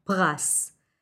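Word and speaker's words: pras (פרס): It's very similar to English, pras. Pei, resh, samech, it's a masculine word, and in the plural, prasim.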